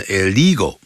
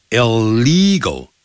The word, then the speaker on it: illegal: 你在音标里头呢查词典的时候呢，他不会把呢这个发音呢他只弄出来 illegal，你可能会念成 illegal，可是呢你一定要记住，外国人他不懂音标的，所以他看 il 的时候，他就先给那第一个 l 给那个 it 的发音叫 l，然后第二个 l 呢念成 l， illegal，就好像一百万叫 one million，第一个 l 给那个 mil，第二个 l 呢 yen， million 啊 million million，不要念成 million，不是这样念法啊。